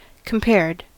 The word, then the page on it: compared: American English